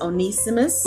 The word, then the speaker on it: Onesimus: Like I said, I think his name is pronounced Onesimus. – What's your spelling? – O-N-E-S-I-M-U-S.